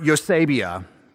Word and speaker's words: eusebeia: So this fifth and final word is the word Eusebia, Eusebia. It's spelled E-U-S-E-B-E-I-A, Eusebia, E-U-S-E-B-E-I-A.